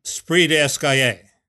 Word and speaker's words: esprit d'escalier: Esprit d'escalier. Precisely. Uh, and you pronounce that well, by the way.